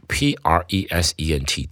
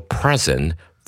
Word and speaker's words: present: 记住 p r e s e n t 这个字念动词是要念成 present，present，当成名词的时候才可以念成 present，present。我们赶快来看一下例题。 The students were excited to present their art project. as a special present for their teacher。